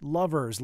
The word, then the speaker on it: LVRS: Lovers followed some more Michigan music from LVRS. Pronounced Lovers, Longer Lust is the name of the track.